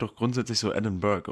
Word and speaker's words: Edinburgh: Edinburgh. Edinburgh, so wie die Briten Bro aussprechen. – Edinburgh.